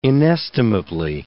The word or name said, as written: inestimably